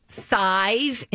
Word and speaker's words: SZA: I think it's SZA, not SZA. The reason I say that is because our bumper music has in parentheses, phonetically, size in giant letters, -uh. – Really? – Yeah. I've always said SZA. – So this was the first time that you tried it as SZA. – Only because... – I understand. The phonetic thing that you saw. – Yeah. – I've always thought it's SZA. It's spelled S-Z-A, correct? – Correct.